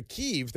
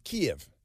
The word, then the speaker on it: Kyiv: And, of course, Kyiv. And if you don't pronounce it Kyiv along with them, then you're not in with the cool crowd. Of course, if you go to Kyiv, they pronounce it Kyiv.